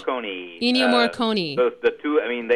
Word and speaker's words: Ennio Morricone: With that amazing, is it Ennio Morricone? How do you say that? – Ennio Morricone.